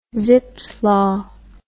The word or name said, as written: zipfs law